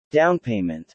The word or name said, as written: downpayment